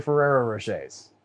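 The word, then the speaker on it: Ferrero Rocher: Okay, well, I mispronounced the name, because it's actually Ferrero Rochers.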